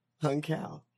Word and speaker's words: Hung Cao: Yeah, Hung Cao.